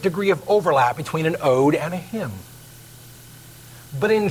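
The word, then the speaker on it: ode (ᾠδή): Hodei, O-D-E, you would pronounce it ode. The Greeks pronounced it ode.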